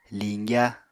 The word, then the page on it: liñgya: Apatani